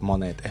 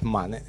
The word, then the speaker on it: Manet: No es Monet, es Manet.